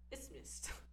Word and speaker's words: isthmus: I looked up Bible. Isthmus. I was kind of close. Isthmus? Isthmus.